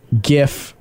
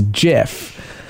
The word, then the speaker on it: GIF: Another incident that I've noticed of people ignoring the facts is the topic of the term GIF versus JIF. Now, it's spelled G-I-F, and it stands for graphical, so you'd think, hey, maybe it's pronounced with a hard G, and most of America accepts that.